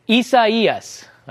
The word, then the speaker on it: Isaias: I'm not saying it wrong. If you're saying it a different way, then you're saying it wrong. This is how you say it. Isaias. All right. I know it's a little tough to say, but that's the proper pronunciation.